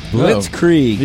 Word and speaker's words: Blitzkrieg: Blitzkrieg. – Oh. Yeah. – Only two syllables.